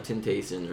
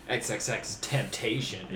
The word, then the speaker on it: XXXTentacion: We have XXXTentacion. I was like, oh, that's how you say it? – I thought it was, like, Tentacion or something at first. – XXXTentacion. – Yeah, that sounds more reasonable.